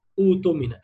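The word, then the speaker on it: اؤتمن: وحال بدء، وده الكلام ده حال البدء فقط، وحال بدء ابدلن، يعني ابدل الهمزه الساكنه، هنا همزه القطع قصده بهمزه الساكنه، ابدلن همزا سكن ياء بايتوني وواوا بايتمن، يعني ابدلها ياء في كلمه ايتوني وواو في كلمه اوتمن او ائتمن.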